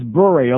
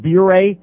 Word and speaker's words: Bure: By the way, Terry Murray, before you leave on that trip, his name is Bure, okay, not Bure.